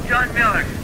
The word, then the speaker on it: John Miller: John Miller. – How do you spell that last name? – M-I-L-L-E-R.